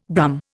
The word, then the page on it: Brum: Indian